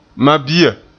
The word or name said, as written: mabia